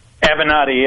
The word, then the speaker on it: Avenatti: Listen, I got to ask you, how do you say this guy's name? Is it Avenatti? Am I saying it right? – Avenatti, yeah.